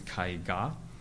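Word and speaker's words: kai ga (絵画): In Japanese, when e is put into a compound word, its pronunciation changes to kai, so it's kai ga.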